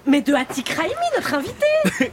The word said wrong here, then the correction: Adil Rami: Comment elle prononce? Adil Rami, c'est le nom.